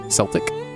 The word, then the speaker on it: Celtic: C-E-L-T-I-C, Celtic Woman, or Celtic, if you prefer that pronunciation.